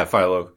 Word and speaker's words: Philo: Yeah, Philo.